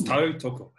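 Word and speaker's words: tautoko: Yes. Tau Toko. So it's Tau Toko.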